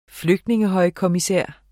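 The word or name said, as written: flygtningehøjkommissær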